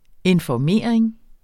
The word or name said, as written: informering